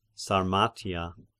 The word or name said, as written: Sarmatia